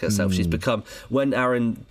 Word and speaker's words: Aaron: It is Aaron because it rhymes with Karen, and I think that in the stage musical they do like a joke about that.